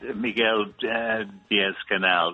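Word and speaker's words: Miguel Díaz-Canel: Miguel Diaz-Canel.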